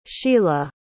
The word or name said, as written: sheilah